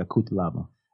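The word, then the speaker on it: Cuțelaba: He brings the action as well against, how do you pronounce that there? Kutalaba? Is that how we're going with it? – Yeah, Kutalaba.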